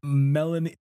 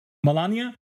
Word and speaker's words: Melania: Anyone want to bet Melanie? How do you pronounce the first lady's name? – Melania.